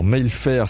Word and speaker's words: Mailfert: Alors Mayfair, ça s'écrit Mailfert @apes-hdf.